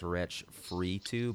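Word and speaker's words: Wretch 32: Yeah, this dude Wretch thirty, or it's called his his his name is Wretch three two, but it's pronounced Wretch free two, but it's like the number three two.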